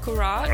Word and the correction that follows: curhat: Nope. It's pronounced Curhat. It's an Indonesian term.